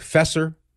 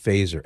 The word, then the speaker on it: Feser: It looks like Fesser, but it's pronounced Faser, F-E-S-E-R.